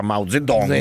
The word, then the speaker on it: Mao Zedong: Tra l'altro sono andato a vedere, hai detto che c'è una montagna con il scolpito, volto, sì, il volto di Yang Mao. – Di Mao Zedong, che si pronuncia Mao Zedong. – Zedong, ok.